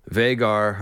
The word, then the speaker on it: Vhagar: However, I yield, I have heard the outcry, and in fact, someone has informed me that George does indeed say Vhagar. I usually try to go with the pronunciations that George gives, so that's how we'll say it, Vhagar.